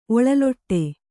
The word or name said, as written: ಒಳಲೊಟ್ಟೆ